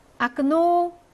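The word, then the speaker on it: 아크노 (окно): 2번, 아크노 창문인데요. 뒤에 오에 강세가 있어서 앞에 오에는 강세가 없으므로 아 발음이 나고요.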